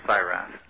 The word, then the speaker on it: Seiwerath: And if you don't mind, my last name is pronounced Seiwerath.